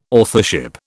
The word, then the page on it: authorship: British English